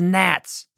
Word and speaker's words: gnats: The next consequence that God sent was gnats. That's spelled G-N-A-T-S. It looks like gnats, but it's not. The G's silent.